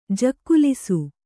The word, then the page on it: ಜಕ್ಕುಲಿಸು: jakkulisu